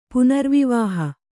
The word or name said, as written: ಪುನರ್ವಿವಾಹ